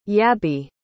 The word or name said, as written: yabby